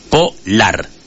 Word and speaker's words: polar: Pregunté a la inteligencia artificial, dice, es una palabra aguda, el acento de intensidad cae en la última sílaba, polar.